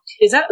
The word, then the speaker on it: Sheisa: ¿Lo dije bien? ¿Sheisa? ¿Sheisa cómo lo pronuncio? – Perfecto. – ¿Sheisa? – Así mismo.